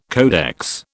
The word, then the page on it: codex: British English